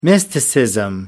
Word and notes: mysticism: American English

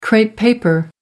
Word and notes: crepe paper: American English

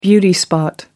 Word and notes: beauty spot: American English

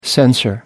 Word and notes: censor: American English

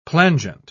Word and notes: plangent: British English

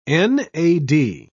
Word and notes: nad: British English

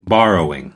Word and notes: borrowing: American English

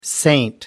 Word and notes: saint: American English

